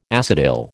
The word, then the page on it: acetyl: American English